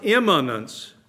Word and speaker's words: immanence: That's immanence. Young people, there are two words that sound so close. One is imminent and one is imminent. And I pronounce those differently, but you might not even have heard that. Imminent and imminent. Imminent and immanent. You almost have to say it slowly to necessarily catch it by ear.